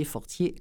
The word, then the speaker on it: Fortier: les titres de Dominique Fortier, qui a été mon invité aujourd'hui, mon invité spécial. Alors, trois titres, Du bon usage des étoiles, Les larmes de Saint-Laurent et La porte du ciel, Dominique Fortier, Fortier, comme ça se prononce.